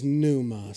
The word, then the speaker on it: pneuma: And then the last one is pneuma, spirit. Pneuma. The P is silent like pterodactyl.